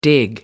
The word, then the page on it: dig in: American English